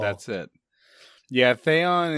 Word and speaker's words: Theon: I know a lot of people say Theon, and I think that that is the correct pronunciation.